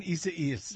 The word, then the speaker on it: Isaias: Hurricane, how do I say this? – Isaias. – I see what you're doing. You're laughing at me. Hurricane Isaias. – There you go.